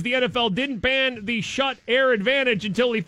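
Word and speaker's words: Schutt: The NFL did not include the, how do you say this, the shut air advantage? It's shut.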